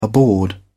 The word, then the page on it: aboard: British English